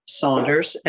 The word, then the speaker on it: Saunders: which is L Y L L A at Saunders, S A U N D E R S dot com.